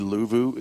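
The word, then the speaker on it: Luvu: Frankie Louvu is, I think that's how you say it.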